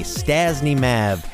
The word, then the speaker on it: Stasney Mav: It's spelled S-T-A-S-N-E-Y-M-A-V, Stazney Mav.